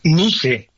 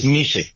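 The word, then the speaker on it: NYCE: ¿Lo dije bien en el CIE o cómo se dice? Nada más para tenerlo bien. – NICE, en realidad es NICE.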